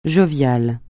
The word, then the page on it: jovial: francais